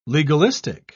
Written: legalistic

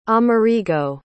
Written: amerigo

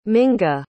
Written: minga

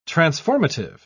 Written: transformative

